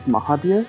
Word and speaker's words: Mahabir: I like my name to be pronounced Rajiv Mahabir.